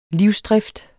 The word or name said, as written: livsdrift